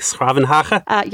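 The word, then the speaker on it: 's-Gravenhage: I actually spent a little time in the Netherlands, and I remember that Den Haag is short for, I'm going to butcher it, but something like Schravenhage? – Yes, I think that. Did I get that right? – That's perfect pronunciation.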